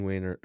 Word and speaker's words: Wehner: But this is like to tell the story of Jean, I'm going to say Wehner, so I'm going to pronounce her name. – Okay. – This is how I got it from a few different people. Jean Wehner, who is the Jane Doe in this case that we'll be talking about as we go along.